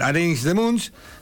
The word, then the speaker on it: Arenys de Munts: Raúl Carrera, hermano de Lalo Carrera, que hace muchos años está viviendo en Europa, fue técnico del Arenys de Munts, de la segunda división del hockey.